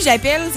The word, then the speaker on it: appelle: Dans le fond, quand on dit j'appelle, c'est deux P, deux L. Pour la simple et bonne raison que on prononce appel, donc on dit E et non E. J'appelle. Contrairement à appeler. Est-ce qu'on comprend la différence de son entre les deux E? Appeler, j'appelle.